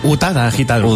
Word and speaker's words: Utada Hikaru: Itada Hikaru ¿Lo he dicho bien? Utada Hikaru Utada, Hikaru.